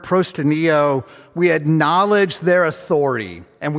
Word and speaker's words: proskuneo: It's the word proskuneo. Proskuneo. It's spelled P-R-O-S-K. U-N-E-O, proskuneo, P-R-O-S-K-U-N-E-O.